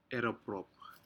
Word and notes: eroprop: Aneityum

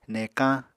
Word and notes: nekañ: Apatani

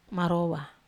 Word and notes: marowa: Wamesa

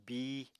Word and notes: bíi: apatani

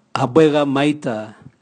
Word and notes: habwega maita: Raga

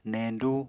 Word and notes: neñdú: Apatani

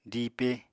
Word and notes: diipe: apatani

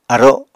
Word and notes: aro: Apatani

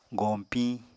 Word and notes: goñpiñ: Apatani

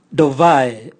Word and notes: dovae: Raga

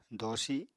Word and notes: doosi: apatani

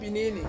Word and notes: pinini: Ansus

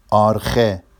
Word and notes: ɑrxe: Vafsi

